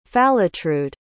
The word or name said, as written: falatrude